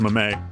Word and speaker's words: meme: Please smack next time he insists meme is a disyllabic French loanword. – Beg your pardon? – Disyllabic French loanword. – Oh, I don't know about that, but it's pronounced meme.